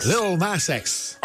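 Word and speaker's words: Lil Nas X: Little Nas X.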